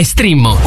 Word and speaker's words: Streammo: Si scrive Streammo o scarica l'app.